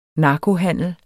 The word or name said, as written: narkohandel